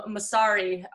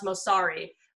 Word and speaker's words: Mosseri: I tweet at Adam Mosseri. I believe it's pronounced Mosseri, but it's the Instagram CEO.